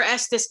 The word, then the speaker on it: Estes: We pronounce that wrong. It's Estes. I think they say Estes. It's named for Estes, Colorado. So we have a friend who's spelled exactly the same way and her last name is Estes. So that's why we pronounce it that way.